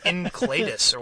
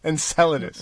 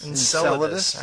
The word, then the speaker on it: Enceladus: I've been saying like Encladus or whatever. How do you say it? – Enceladus. – Enceladus. All right.